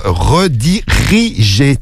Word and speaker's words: redirigé: Redirigé. Tu as oublié redigé. Non, c'est redirigé sur Snap.